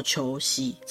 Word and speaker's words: 銚子: 那这个湿仙贝呢，有一个蛮有趣的故事，在千叶县那边有一个叫做吊子市那个地方，这个吊子呢，就是一个金字旁在一个征兆的兆，日文念作秋夕。